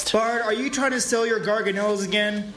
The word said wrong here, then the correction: Gorgonites: It's pronounced Gorgonites. It's disgraceful to pronounce it wrong.